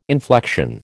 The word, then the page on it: inflection: American English